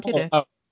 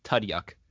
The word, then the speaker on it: Tudyk: You said it correctly, Alan Tudyk. – Okay, because I always say Tudyuk.